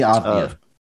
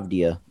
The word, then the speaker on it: Avdija: So you got uh Deni Avid. Uh, how you say his name? – Deni Avdija. – Uh, Avdija. – Yes, yeah, Deni Avdija for the Wizards.